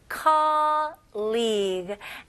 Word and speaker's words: colleague: colleague. 네, 스펠링은 매우 복잡하지만 이게 불어에서 나온 단어거든요. 스펠링은 복잡하지만 그렇게 복잡하지 않아요. call, 그 다음에 league. L-E-E, 뭐 G 이렇게 생각하시면 좋을 것 같아요.